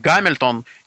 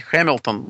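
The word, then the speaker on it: Hamilton: Но, в принципе, наверное, все-таки, хотя принято говорить «Гамильтон» и город там в Канаде, который также называется тоже, но все-таки, наверное, правильно произносить «Хэмилтон».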